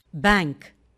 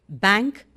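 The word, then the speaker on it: bank: ബാങ്ക്, B A N K, ബാങ്ക് എന്നാണ് British pronunciation. American pronunciation is ബാങ്ക്. N K എന്നത് ങായും കായും ചേർന്ന ങ്ക് ആണ്. ങായും ഗായും ചേർന്ന ങ്ക് അല്ല. ബാങ്ക് or ബാങ്ക്.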